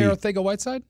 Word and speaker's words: Arcega-Whiteside: And but did you just drop in a perfect pronunciation of Arcega-Whiteside? Instead Yeah, J.J. Arcega-Whiteside.